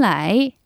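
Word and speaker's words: 라이 (来): 자, 이걸 다 이어붙여서 똥, 취, 춘, 라이 라고 하시면 됩니다. 자, 겨울이 간 지 언젠데 아직 춥냐 이런 생각이 들 때는 겨울이 완전히 떠나야 봄이 온다는 의미에서 똥, 취, 춘, 라이, 똥, 취, 춘, 라이 라고 말씀해 보세요.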